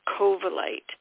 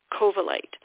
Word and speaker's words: covellite: The last one I'm going to talk about for releasing energy is something called covellite. It's spelled C-O-V, as in Victor, E-L-L-I-T-E, covellite.